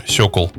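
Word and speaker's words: Sokół: Он стал называться «Сокол 1000 CVS». Кстати, по-русски название читается «Сёкол».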